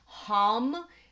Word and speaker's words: ham: throat chakra is hum, which in Sanskrit is spelled H-A-M, but it is pronounced H-U-M.